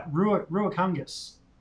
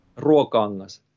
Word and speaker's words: Ruokangas: And this is a, am I going to pronounce it right? Ruakangas. – Ruokangas. – What he said. What he said. – Ruokangas and yeah, it's it's my second Ruokangas.